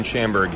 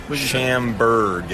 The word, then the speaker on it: Schamburg: Ben Schamberg here. Head brewer. Schamberg, yes.